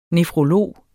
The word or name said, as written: nefrolog